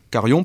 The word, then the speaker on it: Carion: Alors il y a Joyeux Noël de Christian Caron, Carion, pardon, j'ai écorché son nom,